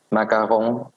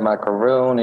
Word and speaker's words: macaron: In French, it would be the pronunciation would be like macaron. – Macaron. – Yeah, um, but I don't get offended if people call it macaroon, if they call them cookies.